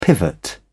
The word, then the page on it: pivot: British English